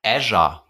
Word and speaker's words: Azure: Azure oder wie spricht man das aus? – Azure. – Azure, ist es wirklich, schreibt man wirklich Azure. Azure, ja.